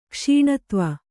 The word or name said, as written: ಕ್ಷೀಣತ್ವ